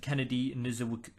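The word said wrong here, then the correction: Nzechukwu: Devontae Smith, Kennedy Neshkukwu.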